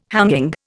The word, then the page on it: hanging: Indian